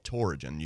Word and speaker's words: Torigen: So I removed the index finger, and we did something called Torigen. You can look it up. It's spelled T as in Tom, O-R-I-G-E-N.